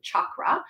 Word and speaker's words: chakra: And if you ever wondered, because maybe you've heard it pronounced both ways, you're going to hear me pronounce it chakra. That is actually the correct pronunciation.